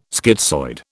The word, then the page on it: schizoid: British English